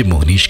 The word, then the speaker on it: मोहनीश: मोहनीश